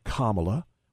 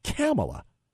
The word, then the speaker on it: Kamala: Brett, who is a regular listener and a regular contributor online to a lot of what I do, Brett Callantine wanted to clear up the pronunciation of Kamala Harris's name, because some people have said Kamala, which is what she prefers apparently, and some have said Kamala, okay, which is what Tucker Carlson said and then got accused of being a racist for.